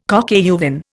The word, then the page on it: coq au vin: Indian